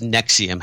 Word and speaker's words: NXIVM: Anyway, this company or cult, pronounced Nexium,